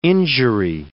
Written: injury

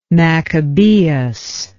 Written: maccabaeus

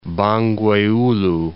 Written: Bangweulu, Lake

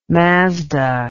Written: Mazda

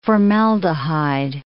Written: formaldehyde

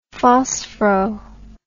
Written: phosphoro

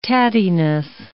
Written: tattiness

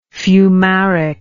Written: fumaric

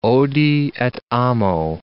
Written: odi et amo

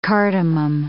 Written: cardamom

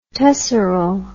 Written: tesseral

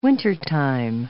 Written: wintertime

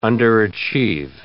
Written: underachieve

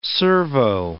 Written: servo

